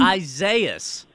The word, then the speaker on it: Isaias: courtesy of Hurricane, how did we say we're pronouncing this storm again? – Isaias. – Yeah, nailed it.